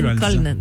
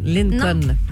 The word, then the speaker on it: Lincoln: Ferme tes yeux, Lincoln. Lincoln.